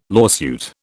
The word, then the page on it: lawsuit: British English